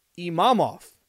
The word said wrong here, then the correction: Imavov: Imavov. Wow, did I butcher that? Nasruddin Imavov.